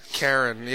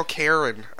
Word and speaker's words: Caron: Caron, yeah. I think I pronounce my name, I have like a nasally, I say Daniel Caron.